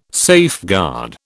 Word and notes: safeguard: British English

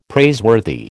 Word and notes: praiseworthy: American English

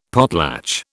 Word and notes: potlatch: British English

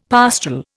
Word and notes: pastoral: Indian

